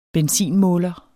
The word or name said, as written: benzinmåler